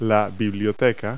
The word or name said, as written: bookcase